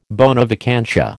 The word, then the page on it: bona vacantia: American English